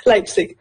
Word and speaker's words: Leipzig: Leipzig, voilà.